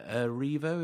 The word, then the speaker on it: Erivo: But the cast is Tom Holland, Daisy Ridley, Mads Mikkelsen, David Oyelowo, Cynthia Erivo.